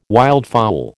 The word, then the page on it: wildfowl: American English